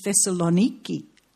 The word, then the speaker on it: Thessaloniki: And the first thing that surprised me when we went there was that our Greek tour guide pronounced it Thessaloniki, spelt with a K.